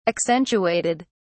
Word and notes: accentuated: British English

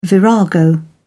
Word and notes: virago: British English

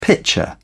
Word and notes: pitcher: British English

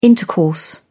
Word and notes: intercourse: British English

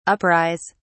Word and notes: uprise: British English

